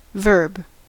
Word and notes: verb: American English

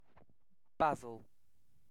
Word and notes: basil: British English

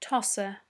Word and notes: tosser: British English

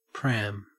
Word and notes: pram: Australian English

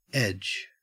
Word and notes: edge: Australian English

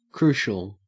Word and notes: crucial: Australian English

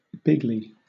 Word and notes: bigly: British English

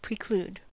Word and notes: preclude: American English

